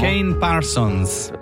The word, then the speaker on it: Kane Parsons: Kane, questo come lo pronunciamo? – Kane Parsons. Non ti piace? – Cane Parsons.